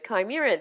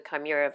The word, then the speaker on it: chimera: So CH is a hard K. Is you? – Well, I'm only going by the way Dougray Scott pronounced it in Mission Impossible 2. – because the reason I pronounce it chimera is because chimney is C-H-I-M, and it's so close that I thought it chimney, chimera, same thing.